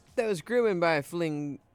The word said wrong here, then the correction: Flamingosis: sorry, I mispronounced that. That was Grooven by Flamingosis and Young Bay.